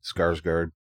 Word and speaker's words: Skarsgård: Bill Sarsgaard? Skarsgård? – Yeah, that sounds right.